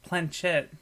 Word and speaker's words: planchette: There are only two things that can move the pointer, the planchette. Is that how you say it? – Planchette, yeah.